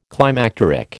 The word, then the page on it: climacteric: American English